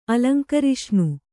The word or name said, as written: ಅಲಂಕರಿಷ್ಣು